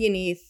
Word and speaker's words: Yanidh: I spell my name Y-A-N-I-D-H, but it's pronounced with a T-H, so it's pronounced Yaneith.